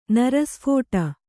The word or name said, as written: ನರಸ್ಫೋಟ